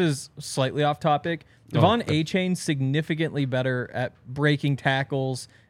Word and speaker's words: Achane: It's Achane. Be unconfident in him. Trust me, it's Achane.